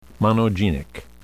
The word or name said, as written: monogenic